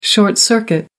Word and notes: short circuit: American English

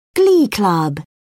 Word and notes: glee club: British English

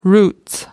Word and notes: roots: British English

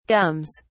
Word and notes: gums: British English

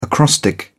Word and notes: acrostic: British English

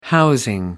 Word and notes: housing: American English

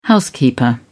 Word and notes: housekeeper: British English

